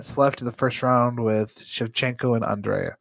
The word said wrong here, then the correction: Andrade: Andrade, is that how you say it? – Yep.